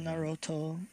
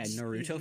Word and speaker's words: Naruto: Naruto is just you putting the stress on the wrong part of the word. I think that's it.